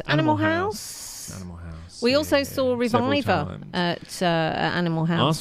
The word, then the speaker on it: RVIVR: That tune was called The Tide, and if you do want to hear more of them, then it's spelled R-V-I-V-R, Reviver.